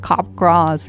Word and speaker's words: Capgras: Pronounced like Capgras. – Very rare. – Capgras. Named after a French. – C-A-P-G-R-A-S.